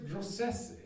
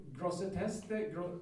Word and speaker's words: Grosseteste: Sen har vi Robert Grosseteste, jag vet inte hur man uttalar sig, Grosseteste, tror jag han heter på franska, Grosseteste eller något sånt. Va? – Grossetest. – Grossetest, ja, det låter som en svensk uttal.